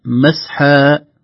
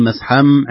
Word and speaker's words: مسحا: لو وقفنا سنقف مسحا ولا ننطق الميم، لن نقول مسحم عند الوقف،